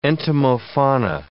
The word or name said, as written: entomofauna